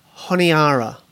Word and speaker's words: Honiara: There's four syllables. Does that help? – Four syllables. – Jesus Christ. – No. – Oh, you better tell us. – I'm just going to have to tell you. – Tell us. – Yeah. – It is Honiara.